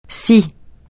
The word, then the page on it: scie: francais